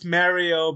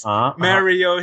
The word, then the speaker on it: Mario: It's pronounced Mario because we interviewed Charles Martinet and he says Mario himself.